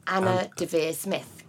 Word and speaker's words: Anna Deavere Smith: Say her name more slowly. – Anna Deavere Smith. – Right, great.